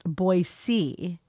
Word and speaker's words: Boise: Also, there is no Z in Boise. It's pronounced Boise.